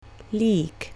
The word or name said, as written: leek